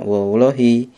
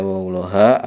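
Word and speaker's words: wallahi: Jadi akhirnya kan dikasroh kan? Wawulohi. Jadi tidak dibaca wawuloha atau wawulohu. Bacanya apa? Wawulohi, demi Allah, itu sumpah ya kan?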